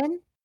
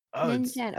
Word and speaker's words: Ningen: How do you say it? The Ningen? – Yeah. It's a recent one. – Ningen. Oh. Wow.